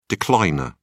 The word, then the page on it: decliner: British English